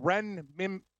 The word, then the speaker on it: renminbi: It's called renminbi? Nah, that doesn't sound right. Currency, Chinese yuan. Oh, no. W-Y-U-A-N. Huh, yeah, it's the renminbi. – Renminbi? – Yeah. – Yeah, it's called renminbi.